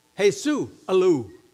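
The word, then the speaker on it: Jesús Alou: Actually, they pronounced it how? Jesus Alou. Jesus Alou.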